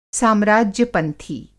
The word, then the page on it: साम्राज्य-पंथी: Hindi